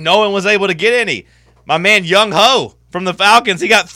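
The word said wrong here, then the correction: Younghoe: Am I pronouncing it wrong? – Yeah, it's pronounced Young Wei.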